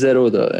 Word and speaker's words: Zerodha: It's it's Zerodha, you know.